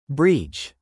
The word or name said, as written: breach